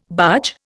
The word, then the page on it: barge: Indian